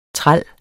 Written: træls

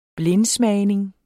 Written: blindsmagning

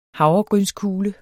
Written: havregrynskugle

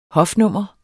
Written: hofnummer

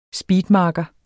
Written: SPEEDMARKERE

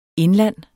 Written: INDLAND

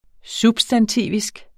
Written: substantivisk

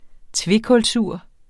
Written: tvekulsur

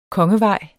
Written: Kongevejen

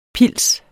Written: pils

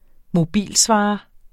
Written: mobilsvarer

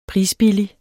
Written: PRISBILLIGERE

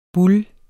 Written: boule

